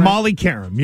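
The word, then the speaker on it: Molly Qerim: Molly Karam, yes. – Karam, okay. It's a Q there. I don't know why it sounds like a K, but that's fine.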